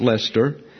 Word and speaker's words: Leicester: For example, you have what would appear to be Worcester, W-R-C-E-S-T-E-R, and it's pronounced Worcester. And you have Bicester, which is pronounced Bister. You have Leicester, pronounced Lester, and Gloucester, pronounced Gloucester.